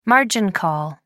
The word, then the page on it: margin call: American English